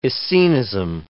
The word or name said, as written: Essenism